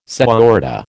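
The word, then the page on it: señorita: American English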